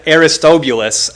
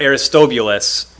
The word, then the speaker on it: Aristobulus: Aristobulus was Herod the Great's son, but Herod the Great had Aristobulus executed. I know I said that two different ways, but I think Aristobulus is the correct pronunciation.